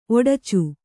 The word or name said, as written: ಒಡಚು